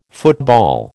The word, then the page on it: football: American English